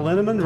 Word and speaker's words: Linneman: The equally mysterious Tracy Z, Aviva L, Alicia Lemus, Rebecca Hughes, Val Linneman, Rhymes with Cinnamon,